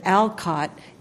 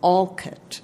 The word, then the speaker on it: Alcott: And if you said Alcott, he would immediately correct you. It was Alcott.